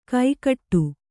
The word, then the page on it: ಕೈಕಟ್ಟು: kaikaṭṭu